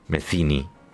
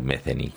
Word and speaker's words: Metheny: Se escribe Metheny con TH. Se pronuncia, aprendí de grande, Metheny. Le dije mucho tiempo Metheny, como todo el mundo.